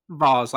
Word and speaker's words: Vols: Vols, like Volunteers.